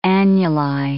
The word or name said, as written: annuli